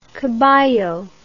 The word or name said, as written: caballo